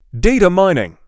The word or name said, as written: Data mining